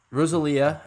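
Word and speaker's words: Rosalía: So Rosalia. I said that right this time? – Yes, you did.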